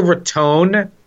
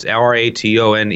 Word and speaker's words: Raton: of Boca Raton with an E on the end because that's how you pronounce it. So if it's R A T O N E, um, you, you, hear a lot of people say Raton, no, uh, or Ratton if you're from somewhere in uh, the Midwest.